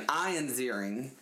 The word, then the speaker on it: Ian Ziering: Ian Ziering, like, who knew, not Ian, Ian Ziering, that's how you pronounce his name.